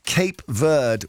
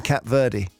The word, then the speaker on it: Cape Verde: Cape Verde, which is the correct pronunciation, are... – not Verde. – No, and I said... Verde. Literally, 60 years I've been saying Cap Verde.